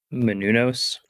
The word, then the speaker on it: Menounos: Menounos.